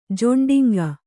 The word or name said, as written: ಜೊಂಡಿಂಗ